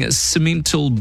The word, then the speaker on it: Simmental: 20 yearling Simmental bulls, that's how you pronounce it,